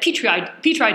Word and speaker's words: Petri: Yes, Petri spelled P-E-T-R-I. – Like the dish.